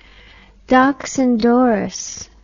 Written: deoch an doris

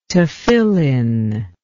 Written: tephillin

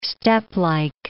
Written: steplike

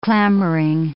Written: clamoring

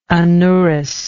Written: anourous

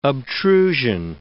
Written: obtrusion

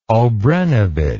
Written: obrenovich

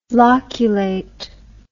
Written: loculate

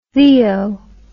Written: theo

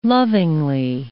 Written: lovingly

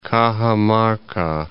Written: Cajamarca

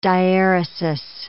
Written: diaeresis

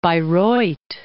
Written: Bayreuth

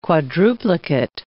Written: quadruplicate

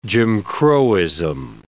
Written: Jim Crowism

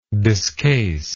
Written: discase

